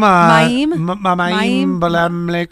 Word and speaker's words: Mayim Bialik: Mayim Bialik.